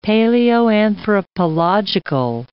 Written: paleoanthropological

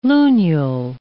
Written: lunule

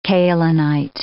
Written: kaolinite

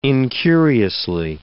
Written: incuriously

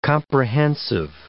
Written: comprehensive

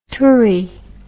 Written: tourie

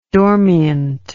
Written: dormient

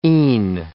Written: e'en

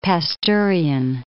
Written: Pasteurian

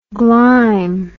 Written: glime

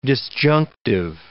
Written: disjunctive

